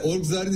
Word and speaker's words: Orgzar: Orgzar'ın ismini doğru söylüyor muyum? Doğru telaffuz ediyor muyum? – Evet doğru telaffuz ediyorsunuz.